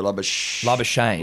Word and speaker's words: Labuschagne: I think I've heard it rhymes with champagne. So Labuschagne.